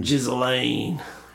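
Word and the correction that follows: Ghislaine: It's Gislaine.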